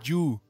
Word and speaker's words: You: No se dice Joe, se dice ah. You.